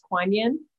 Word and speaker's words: Kuan Yin: And just for others, I know what you're referring to as Quinlan as Kuan Yin, but it's the same consciousness.